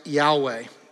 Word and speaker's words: Yahweh: that is actually the name of God, which is pronounced Yahweh.